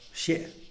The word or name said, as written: xie